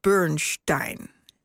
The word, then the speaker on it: Bernstein: Maar goed, voor zover hebben kunnen achterhalen, wilde de dirigent zelf dat zijn achternaam werd uitgesproken als Bernstein.